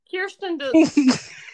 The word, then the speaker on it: Kirsten Dunst: Kirsten Dunst.